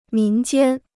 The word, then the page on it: 民间: mín jiān